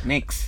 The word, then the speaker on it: Mx: How do you pronounce it firstly? Mx. Mix. – It's MX, yeah. – So what do you say? – You don't make a sound, mix. – Yeah, there's no vowel.